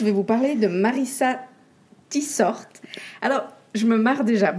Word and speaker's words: Tietsort: Et en fait, pas du tout, on prononce ça Tit Sort. Voilà.